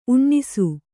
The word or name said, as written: ಊನೈಸು